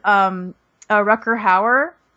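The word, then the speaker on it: Rutger Hauer: Speaking of, can I just add something that I thought was hilarious was Rucker Hauer.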